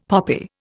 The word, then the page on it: poppy: Indian